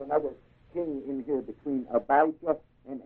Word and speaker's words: Abijah: Now under Judah, across from Jeroboam, the king reigned in Judah along with Jeroboam in Israel, was a king named Abijah, A-B-I-G-J-A-H, Abijah. Is it J or G? J-A-H. Long I. Abijah. That's good. And Ahab, the king for Judah was Jehoshaphat. J-E-H-O-S-H-O, I guess.